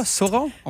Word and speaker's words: Sauron: C'est ça, Sauron? On prononce ça de même? – On est dans ma poche.